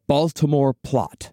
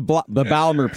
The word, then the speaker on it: Baltimore: Well, this first attempt came to be known as the Baltimore Plot. – Can you actually say it properly? The Baltimore Plot. – The Balmer Plot. – Balmer Plot. – Balmer Plot, yeah.